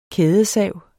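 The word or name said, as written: kædesav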